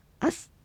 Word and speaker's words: アス: そうですね、ちょっとね、一瞬こう詰まる音っていうか難しいんですけど、それ以外にですね、この S のシーンの、紹介でちょっと面白いものがっていうか、なるほどなと思うのがあるんですけど、アスっていう発音があります。ローマ字で書くと AS、これはアイヌ語で立つという意味です。